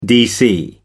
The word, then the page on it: dc: American English